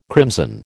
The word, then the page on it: crimson: American English